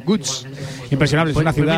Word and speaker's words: Łódź: ¿Sabes cómo se pronuncia Lotz? Te lo voy a decir, es que es muy difícil, Borja. Gutsch.